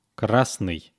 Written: krasnyy

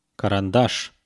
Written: karandash

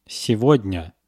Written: segodnya

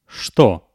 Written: chto

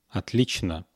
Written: otlichno

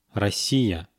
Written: Rossiya

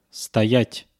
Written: stoyat

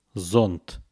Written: zont

